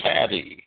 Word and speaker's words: tsadi: it's spelled T-S-A-D-I. In English is the name of the letter, and it represents a T-S sound that oftentimes in English we kind of say as a Z, which is why in the King James Bible you will see Sidon, S-I-D-O-N, in a lot of places, and you will see Zidon, Z-I-D-O-N, in a lot of places.